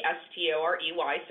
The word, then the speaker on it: Storey: It's R O B Y N N Storey, S T O R E Y, so Robynn Storey.